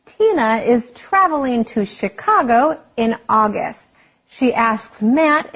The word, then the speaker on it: Chicago: 这些都是 CH 发 CH 的音。不过因为 Chicago 这个字是从法文来的，所以要念成 Chicago。CH 发成 SH 的音，所以不要念错咯。我们偶尔还是会听到有人念错。类似的字还有像机器 machine, 主厨 chef, 香槟 champagne, 还有像简介的小册子是 brochure, 这些字都是 CH 发成 SH 的音。